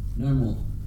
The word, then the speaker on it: Namor: Namor.